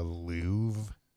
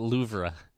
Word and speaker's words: Louvre: You might know of it as the Louvre. – The Louvre. – Yeah.